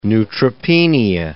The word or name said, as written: neutropenia